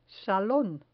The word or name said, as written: salon